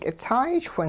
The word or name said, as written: kitsaay-chwing